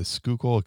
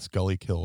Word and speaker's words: Schuylkill: Like a 20 minute, for those not from Philly, we have the Schuylkill Expressway, which people call the Scully Kill or whatever, you know, mispronounce it, but, it should take you 20 minutes to do that drive.